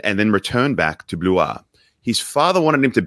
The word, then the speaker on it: Blois: He was born in a place in France called Blois, which I had to look up because it's spelled B-L-O-I-S, not Blaus. It's actually pronounced Blois.